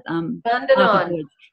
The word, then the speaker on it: Bundanon: Tell me about your residency at, I don't know how to pronounce it, down at Bundanon.